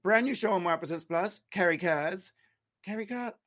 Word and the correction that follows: Carrie Cares: am I just saying it wrong? Is this the KK thing? – It's Carrie cares. She cares.